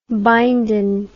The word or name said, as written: bindin